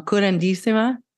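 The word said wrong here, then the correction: curanderismo: the healing. – So it's called curanderísimo.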